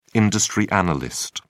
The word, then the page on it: industry analyst: British English